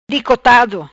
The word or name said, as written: Dicotado